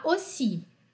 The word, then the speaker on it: aussi: Aqui esse AU é O, né? Moi aussi.